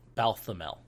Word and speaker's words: Balthamel: Okay, how do you say that? – Balthamel? – The second... Balthamel. Thank you. – Balthamel? That's how we always said it.